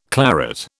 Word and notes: claret: British English

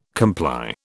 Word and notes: comply: British English

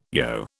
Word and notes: yogh: British English

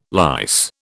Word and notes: lice: British English

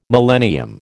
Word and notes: millennium: American English

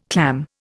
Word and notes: clam: Indian

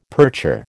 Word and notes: percher: American English

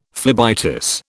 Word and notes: phlebitis: British English

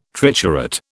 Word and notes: triturate: British English

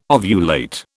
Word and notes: ovulate: British English

